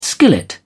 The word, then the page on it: skillet: British English